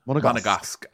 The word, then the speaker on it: Monegasque: Monegasque.